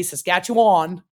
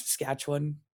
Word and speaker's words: Saskatchewan: You always know when somebody does not live in Saskatchewan or Canada because they say Saskatchewan. And it's Saskatchewan. – Okay.